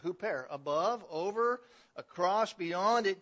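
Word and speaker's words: huper (ὑπέρ): In the original language, it is the preposition huper, H-U-P-E-R, if you wanted to spell it in English letters, huper.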